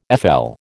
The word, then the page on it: FL: American English